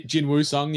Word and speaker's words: Jinwoo Sung: And we see that especially with the main character, Jinwon Sung, right? That's how you say it? – Jinwoo Sung, yeah.